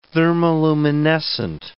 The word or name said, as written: thermoluminescent